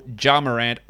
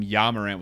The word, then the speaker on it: Ja Morant: Steph Curry, Anthony Davis, Nikola Jokic, LaMelo Ball, Ja Morant, Russell, almost called him Ja Morant with a soft J, Russell Westbrook, Kevin Durant, Jimmy Butler, D'Angelo Russell, and the big avocado, Andre Drummond.